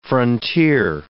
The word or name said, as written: frontier